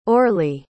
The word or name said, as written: orley